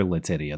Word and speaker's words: Letitia: And I think we call her Letitia. That's the correct pronunciation.